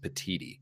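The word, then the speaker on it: Petitti: By the way, I believe the new Big Ten commissioner's name is pronounced Petiti.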